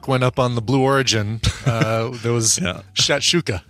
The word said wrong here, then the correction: shakshuka: I'm actually pronouncing it wrong. It's shakshuka. I keep saying shat with a T. It's shakshuka. Yeah, more like Shaq O'Neal, you know, like a basketball great Shaquille O'Neal's shortcut name.